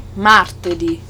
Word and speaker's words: martedì: Tuesday, martedì.